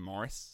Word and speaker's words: Maurice: It's Morris, but it's spelt like it's Maurice, but the Australians say...